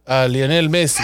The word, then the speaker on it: Lionel Messi: Lionel Messi.